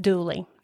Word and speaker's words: Dula: Tom Dooley, and that is actually spelled Dula, D-U-L-A, but this is the Appalachian Mountain area, and so everybody pronounces that Dooley.